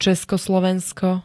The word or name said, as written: ceskoslovensko